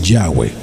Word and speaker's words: Yahweh: Es de otra forma que se concluye la única y perfecta pronunciación o más acercada es Yahweh.